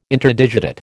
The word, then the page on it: Interdigitate: American English